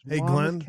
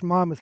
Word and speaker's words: Monmouth: You didn't butcher the name of the county too bad. It's Monmouth County, but that's not too bad.